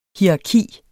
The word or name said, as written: hierarki